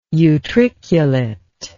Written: utriculate

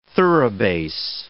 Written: thoroughbass

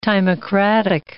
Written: timocratic

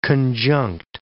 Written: conjunct

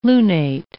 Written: lunate

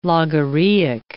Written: logorrheic

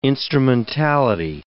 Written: instrumentality

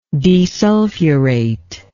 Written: desulfurate

